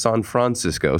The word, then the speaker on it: San Francisco: She's joining us remotely from San Francisco, or as I believe it was pronounced, San Francisco. Someone told me earlier today. – That's the correct pronunciation.